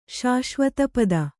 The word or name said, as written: ಶಾಶ್ವತಪದ